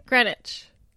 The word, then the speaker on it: Greenwich: Did I say that right? – Greenwich. – I better get it right before I actually go to New York. How come there's a W in there if it's...